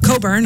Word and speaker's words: Cockburn: In this final hour for me, I got music from, let's see, Dentist, Bruce Cockburn is coming up. Coburn, I always say that wrong, and they always yell at me for that.